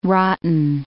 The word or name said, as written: rotten